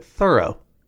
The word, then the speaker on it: Thoreau: They had a different pronunciation for Thoreau. And they said the family pronounces it Thoreau.